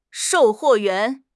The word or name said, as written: shou4 huo4 yuan2